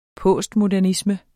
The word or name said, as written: postmodernisme